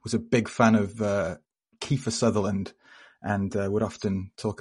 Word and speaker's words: Kiefer: It's Kiefer, not Keither. So just for the Keitherites, Sutherland stands. – Yeah, it's not a silent F, listeners, so don't get caught out.